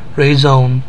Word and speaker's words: Raisin: So we find out that, and then it goes on to say that he thinks it's ironic that the French company Raisin, pronounced Raison, means reason, might one day produce a virus that would bring the world to its knees.